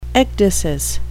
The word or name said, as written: ecdysis